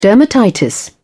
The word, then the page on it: dermatitis: British English